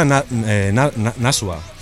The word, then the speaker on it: Nashua: Pues se pronuncia Nashua, si no estoy mal informado, pero nosotros lo llamamos cada uno un poco como le viene bien, según el día. O sea, normalmente lo decimos Nashua, ¿no? Con el acento en la U, nos gusta más.